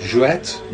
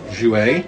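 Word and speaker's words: Jouët: And Perrier, Jouet, how do you say it? – And it's Jouet, not Jouet.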